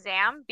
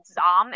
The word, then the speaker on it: Zam: And so it makes sense for Star Wars that her first name would be pronounced Zam because like that's a very Star Wars way of pronouncing things. But like in terms of the Middle East, it would have been Zom, if that makes any sense.